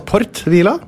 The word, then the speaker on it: Port Vila: Ja, det uttales Port Vila.